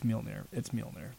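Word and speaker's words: Mjölnir: It's Mjolnir.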